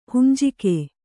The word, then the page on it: ಹಂಜಕ: hanjaka